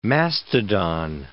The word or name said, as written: mastodon